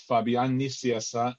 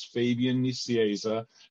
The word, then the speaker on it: Fabian Nicieza: The way I say it is in Spanish, it's Fabian Nicieza. In English, it's Fabian Nicieza. And often in American, it's Fabian Nicunza.